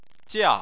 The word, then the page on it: jia4: chinese